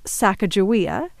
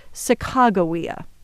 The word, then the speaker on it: Sacagawea: This young woman is usually called Sacagawea, but one of the things I learned on this trip is that her name was probably actually pronounced Sacagawea.